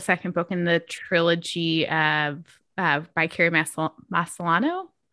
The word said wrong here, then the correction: Maniscalco: Maniscalco. – Yes, Maniscalco. Thank you. I did look up YouTube pronunciation guides to that and I did it wrong.